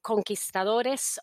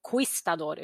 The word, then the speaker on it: conquistador: Och sedan så jobbar jag själv som universitetslektor i spanska, så jag måste ju påpeka att när ni pratar om conquistadores och la conquista eller Reconquista, så ska man inte uttala U-et, så det heter inte conquistador utan conquistador.